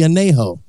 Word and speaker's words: Añejo: First one is the Añejo. That's how you pronounce it.